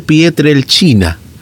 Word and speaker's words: Pietrelcina: Ay, sí, ¿cómo se pronuncia? Pietrelcina, con CH, me dice. Ah, perfecto. Entonces, San Pío de Pietrelcina. Así se pronuncia.